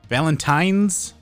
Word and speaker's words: Valentine's: Valentine's with an N, not an M.